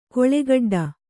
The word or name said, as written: ಕಾಳುಕಡ್ಡಿ